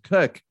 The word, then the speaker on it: Koch: you know, my my last name is spelled K-O-C-H and we pronounce it Cook.